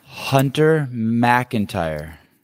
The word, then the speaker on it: Hunter McIntyre: Hunter McIntyre.